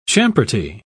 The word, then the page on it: champerty: British English